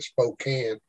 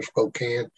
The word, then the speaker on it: Spokane: John Lake had those healing rooms up in, how do y'all pronounce it? It looks like Spokane. I think y'all call it Spokane or something like that. Kick the can, Spokane.